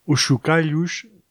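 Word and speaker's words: Uxukalhus: Uxu Callus sería más o menos la pronunciación en portugués. De lo que he escrito veríamos más bien como Os Chocallos, así se pronunciaría también en gallego, por ejemplo. Pero ellos lo escriben así, Uxu con X, Callus con K, bueno, un poco a su manera.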